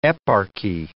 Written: eparchy

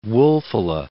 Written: Wulfila

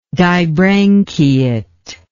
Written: dibranchiate

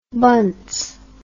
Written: bunce

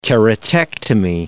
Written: keratectomy

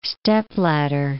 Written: stepladder

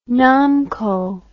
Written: Namco

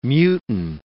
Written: mutine

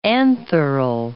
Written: antheral

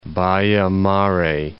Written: Baia-Mare

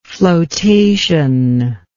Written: floatation

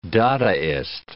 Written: dadaist

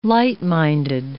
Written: light-minded

